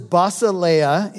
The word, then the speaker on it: basileia: The word is basileia in Greek for kingdom. It's spelled B-A-S-I-L-E-I-A, basileia.